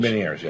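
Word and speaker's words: Ménière's: I have been suffering with Meniere's for a year now. Is that the right pronunciation? – Meniere's, yeah.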